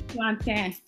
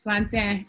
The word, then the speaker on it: Plantin: Plantin. – Plantin? – French. Plantin. Yeah.